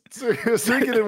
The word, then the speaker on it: Suikoden: Suikoden 1 and 2 HD remasters have been announced. This comes from Saul Romano at Gamatsu. Konami has announced Suikoden 1 and 2 HD remaster, Gate Rune, and Dunen Unification Wars for PS4, Xbox One, Switch, and PC, launching in 2023.